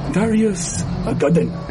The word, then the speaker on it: Darius Gaiden: How do you say that in Japanese? – Darius Gaiden.